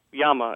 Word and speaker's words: llama: I've always pronounced this word llama, because in my Spanish classes in school, I always learned that the double L in Spanish is pronounced like a Y, yeah, like in the words tortilla, tomatillo, pollo, which means chicken, or caballero, which means gentleman.